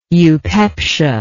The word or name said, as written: eupepsia